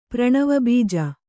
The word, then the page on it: ಪ್ರಣವಬೀಜ: Kannada